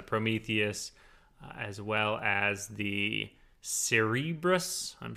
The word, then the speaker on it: Cerberus: I think they said, I know it as Cerberus, but I think they even said it like on the show, like a Cerberus or something like that. So I think you're okay with how you pronounced it earlier.